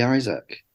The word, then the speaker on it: Isak: By the way, I realised this week that it is actually Isak. – Is it? – Because I saw him pronounce his own name.